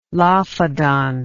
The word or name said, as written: lophodont